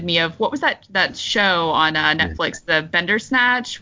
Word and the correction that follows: Bandersnatch: with Black Mirror, Bandersnatch? I'm probably saying that wrong. Do you know what I'm talking about? Yeah. – Yeah, I think that's what it was called, Bandersnatch, yeah.